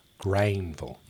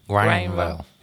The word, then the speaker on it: Greenville: I am not a native of Greinville. – Greinville. – Yes. G-R-A-I-N. V-U-L-L. – That's how you say it around here. In these parts.